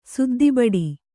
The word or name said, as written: ಸುದ್ದಿಬಡಿ